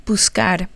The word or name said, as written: buscar